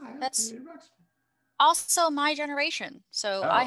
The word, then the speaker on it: Ruxpin: Wait, am I saying the name wrong? – Yeah, it's Teddy Ruxpin. – Oh, Ruxpin. Teddy Ruxpin. My apologies.